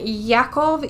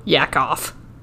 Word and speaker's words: Yakov: Then Yakov Yurovsky, a member of the Bolshevik secret police, what did I pronounce it wrong? – Yakov.